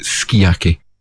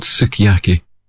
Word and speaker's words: sukiyaki: It's called Sukiyaki, as the Japanese are more likely to pronounce it Sukiyaki, but it was called Sukiyaki in English.